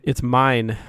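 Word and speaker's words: Myne: Waifu is it mine? Is that how you say it? – It's mine.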